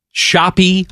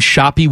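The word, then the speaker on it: Shoppe: I was the ice cream shoppy with an E at the end. I always call it the shoppy. – That's pronounced, unlike Drew Timmy, it is just pronounced shop.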